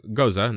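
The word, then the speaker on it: Gose: Goza. No, it's spelled Goza. Like goes, but it said Goza.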